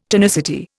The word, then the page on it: tonicity: Indian